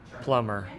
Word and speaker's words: Plumer: I pronounce it Plumber. – Plumber, named after a guy named Nathaniel Plumber, who was the president of the Southern Arizona Bank.